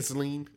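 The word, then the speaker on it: Ghislaine: How you say her name? – Gisling. – Gisling. Gisling.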